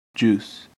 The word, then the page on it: juice: American English